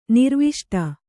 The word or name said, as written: ನಿರ್ವಿಷ್ಟ